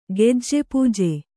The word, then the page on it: ಗೆಜ್ಜೆಪೂಜೆ: gejje pūje